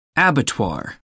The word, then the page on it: abattoir: American English